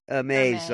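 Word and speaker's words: Hermès: Hermes, I believe, yes.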